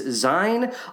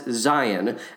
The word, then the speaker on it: Zien: Now, I'll, I'll just pause right there because I think a lot of people think it's Zine. A lot of people think it's Zine. I specifically went to YouTube and found a clip of him pronouncing his own name, and he pronounces it as Zion.